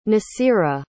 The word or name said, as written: nasira